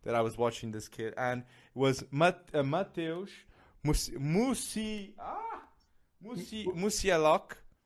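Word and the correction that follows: Musiałowski: Polish. Okay, I don't know if I'm butchering this name. – Musialowski, I think. Yeah. – Musialowski, okay. Mateusz Musialowski, okay.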